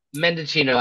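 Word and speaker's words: Mendicino: What's his name? Manicito. Is that how you say it? – Oh, in fact, I'm going to talk about him, but yes, He's out. – I do believe Mendocino.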